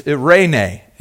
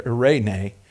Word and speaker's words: eirene: But it's it's eirene is the word. It's how you pronounce that word right there, eirene.